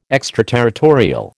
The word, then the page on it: extraterritorial: American English